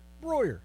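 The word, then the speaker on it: Brauer: Denny Breyer. – Breuer. – Denny Breuer Signature Series.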